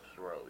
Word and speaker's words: Thoreau: Henry David Thoreau. – Thoreau, yeah.